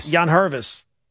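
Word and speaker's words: Yangervis: Yanvervis.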